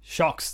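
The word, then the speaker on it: Sjokz: Yeah, I remember the pronunciation. Shokes. I was like, really? Is Shokes. That what it is? Shocks, that is. Shocks. – Shocks. There we go.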